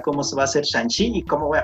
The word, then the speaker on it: Shang-Chi: Hay que, eh, durante la semana, eh, el actor principal tuiteó que la pronunciación correcta, de hecho, es Shang-Chi o no y creo que toda la prensa ha estado tratando de corregir el error de pronunciación. Ah, pero sí, bueno, ese, ese dato.